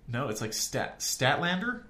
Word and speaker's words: Statlander: Statlander? Yeah, that's how you pronounce it.